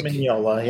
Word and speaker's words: Mignola: Mignola? – Mike Mignola, yeah.